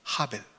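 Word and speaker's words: 하벨 (הֲבֵל): 그래서 이게 헤벨이라고 발음이 되지 않고 하벨이라고 발음이 됐습니다. 사실 저처럼 발음하면 안 되고 R을 길게 발음하면 안 됩니다. 하벨, R을 짧고 약하게 발음을 해야 됩니다.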